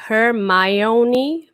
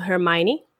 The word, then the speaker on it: Hermione: Cuja pronúncia do nome é Hermione, mas geralmente a gente escuta os atores falando Hermione no filme.